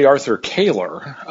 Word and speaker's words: Arthur Koehler: It's actually Arthur Koehler, and that's one of the reasons no one ever knew who this guy was.